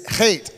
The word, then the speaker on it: chet: If I said that right, it's like a hairball. It's chet. That's how you say it.